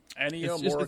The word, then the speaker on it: Ennio Morricone: Ennio Morricone. It's cool. Yeah, he's up there in age. – That's how you say his name, yes.